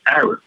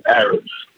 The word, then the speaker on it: Arab: Arab. Clearly Arab. You know, it's like the people, Arabs. – Right. – You know. – See, that's how I should have figured out the pronunciation. I just should have stuck with with the schooling. But I remember SERP saying that as a Rab, and that's how it stuck.